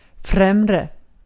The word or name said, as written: främre